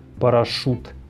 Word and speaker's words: парашют: Интернет, произносим «э», фонетика, снова «э», а также слова с шипящими согласными, такие как парашют, шина, железо где мы пишем ю и Е, но произносим твердые У и Э.